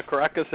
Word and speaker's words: Kerekes: Diana Kerekes is our special guest this evening.